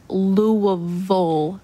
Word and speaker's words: Louisville: Anything that has a vill at the end, they say vull, like Louisville, Kentucky. It looks like Louisville or Louisville, but Louisville.